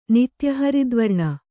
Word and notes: ನಿತ್ಯಹರಿದ್ವರ್ಣ: kannada

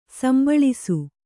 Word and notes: ಸಂಭಾಳಿಸು: sambhāḷisu

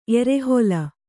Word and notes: ಎರೆಹೊಲ: erehola

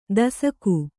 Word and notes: ದೇಸಾಕೆ: dēsāke

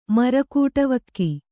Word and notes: ಮರಕುಟವಕ್ಕಿ: Kannada; mara kuṭavakki

